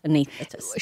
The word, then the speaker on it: anaesthetist: Yeah, so yesterday I got a call from a nurse, an anaesthetist nurse, and she says we never pronounce the first S in the word. It's always just anaesthetist.